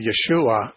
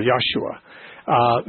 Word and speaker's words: Yeshua: Yeah, well, I mean, Joshua, or more properly, Yeshua, would be the more Hebrew pronunciation, because the Hebrew language doesn't have a J sound. So the Hebrews would never say Joshua. They'd say Yeshua or Yahshua.